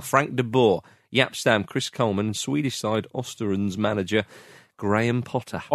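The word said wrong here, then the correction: Östersund: Ostersons. And you're a host of On the Continent, so you knew the correct way to pronounce that.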